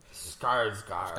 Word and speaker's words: Skarsgård: Skarsgård.